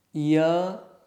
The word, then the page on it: y: sanskrit